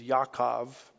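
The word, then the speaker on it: Yaakov (יעקב): The Hebrews would, I guess, pronounce his Hebrew name as Yaakov or Jacob, you know, just like the Jacob you see in the book of Genesis.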